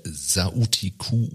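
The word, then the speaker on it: Sauti Kuu: Obama Foundation, äh, Sautiku, ja. habe ich das richtig ausgesprochen, ja. Ja.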